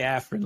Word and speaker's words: Afarin: You can just say Afarin, like that nose spray.